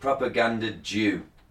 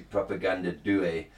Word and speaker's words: Propaganda Due: And speaking of mispronouncing, I thought this was called Propaganda Jew, D-U-E. Then I realised it's an Italian conspiracy, and it's actually Propaganda Due. The Italian word for two.